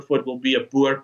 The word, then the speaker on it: Boer: And the citizens of these two republics call themselves Boers, or Boer, as we will pronounce it in Afrikaans.